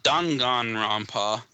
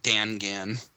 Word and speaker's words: Danganronpa: This is also from Destructoid, but Danganronpa, not Dangan. – I didn't say Dangan, did I? – Yeah. – Oh, I'm sorry. – Or you said Dangan or something like that. – I'm sorry. – Japanese syllables. Stick to the Japanese phonics.